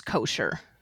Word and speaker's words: kosher: What is kosher? – Yes, so kosher is the Ashkenazi or the European pronunciation of the word, the Hebrew word kasher, which means to be fit.